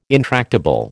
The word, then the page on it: intractable: American English